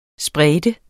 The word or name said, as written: sprede